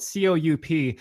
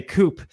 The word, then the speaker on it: coup: Because by the way, the word coup, it's spelled COUP. I've heard some people say coup. It's called a coup, but one thing communists will accuse us of, or accuse capitalists of is that we intervene in countries a lot.